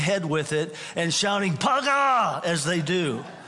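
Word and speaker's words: paga (פגע): Now, in the Old Testament, the word for intercession is the word paga. Okay, that's the Hebrew word. You would pronounce it paga.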